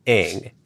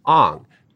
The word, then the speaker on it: Aang: The main character, the avatar, is named Aang in the show. In three seasons of the show, it's always Aang. However, in the film, Shyamalan decided to pronounce it Ong.